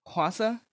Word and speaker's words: Hwasa: But Hwasa, did I say that right? – Yes.